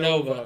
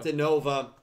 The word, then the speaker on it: Dinova: Dinova? That's how you say it? – Dinova. – Dinova. – Like Nova, but D before it.